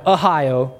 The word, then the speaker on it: Ohio: Uzzah and Ahio, and let me just say this for those of you who've never been to Southern Ohio, this is how you pronounce Ohio in Southern Ohio. Ahio. It's very biblical.